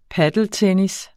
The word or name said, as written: padeltennis